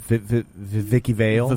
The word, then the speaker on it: Vicky Vale: If she was going to be Vicky Vale it's pronounced Vicky Vale. Vicky Vale.